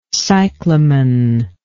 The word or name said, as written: cyclamen